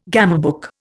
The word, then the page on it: gamebook: Indian